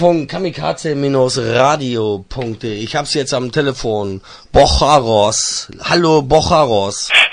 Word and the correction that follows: Borrachos: Gut ausgesprochen. Das heißt Borrachos. – Borrachos? Ja. Stimmt. Ah, das ist ja Spanisch, Mann.